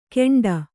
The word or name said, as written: ಘೇಂಡ